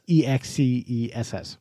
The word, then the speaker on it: INXS: INXS is the way it's spelled, but it's pronounced INXS like INEXCESS.